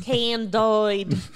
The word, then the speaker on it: Candide: Candide. – Well, it's a French pronunciation. – Candide.